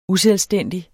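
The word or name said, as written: USELVSTÆNDIG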